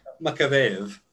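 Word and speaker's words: Makavejev: Makaveyev.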